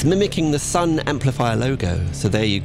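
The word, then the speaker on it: Sunn O))): Blimey, it's just Sun, not Sun O. You just say Sun.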